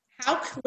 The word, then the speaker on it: Hawk: Hawk. It's like how with a K on the end, huh? – Hawk.